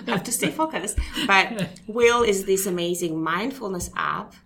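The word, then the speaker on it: Whil: And going back to Will, I feel like we yeah.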